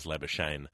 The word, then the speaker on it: Labuschagne: So he tells people his name is Labuschagne.